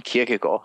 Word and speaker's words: Kierkegaard: Søren Kierkegaard. – Søren Kierkegaard. – But this was very good. – Yeah. Almost. – Don't say the D.